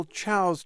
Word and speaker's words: Tso: When you get to China, you might learn that you're pronouncing it wrong. It's General Chow's chicken, and next you learn you can't get it.